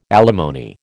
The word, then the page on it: alimony: American English